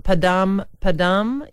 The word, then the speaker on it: Padam Padam: It is called Padam Padam. Yes, I have said that right.